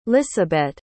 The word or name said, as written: lisabet